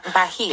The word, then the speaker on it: Bahia: So you came back from, am I saying it correctly? Bahia. – Bahia.